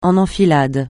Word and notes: en enfilade: français

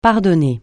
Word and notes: pardonner: francais

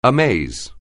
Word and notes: amaze: anglais

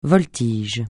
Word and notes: voltige: francais

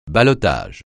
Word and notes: ballottage: francais

